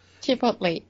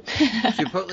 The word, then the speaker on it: Chipotle: Chipotle is wrong. Chipotle is correct.